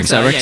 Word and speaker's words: Rexha: Is it BB Rexha or BB Risa? I never really knew how to say her name. – Rexha.